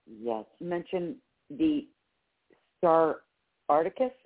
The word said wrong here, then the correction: Arcturus: Is that what you said? Can you spell that? – Oh. Yes, Arcturus. It's Arcturus. Let me just make sure I get that.